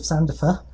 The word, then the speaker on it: Sandifer: But we've got Elizabeth Sandifer. I'm pronouncing your second name right, aren't I? Sandifer? – Yes, yes. Okay. Very good. – Like Lucifer, but with sand.